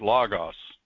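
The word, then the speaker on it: logos (λόγος): The word in the Greek language, they pronounced it logos.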